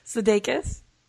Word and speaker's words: Sudeikis: How'd you say his name? – Sudeikis. – Sudeikis.